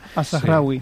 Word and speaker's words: Al-Zahrawi: Pues mira, el primero que he traído es Al-Zahrawi, ya me dirá si lo he pronunciado bien o mal. – Al-Zahrawi. – Sí. Perfecto, pues pero lo he pronunciado bien. Mal. Es una buena manera de no decírmelo abiertamente. – Sí, no, bien, bien.